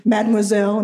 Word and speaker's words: Mademoiselle: Vogue and Mademoiselle.